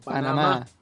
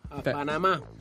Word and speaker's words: Panama: Che qua si dice con l'accento sulla ultima A, qua è a Panama. Panama.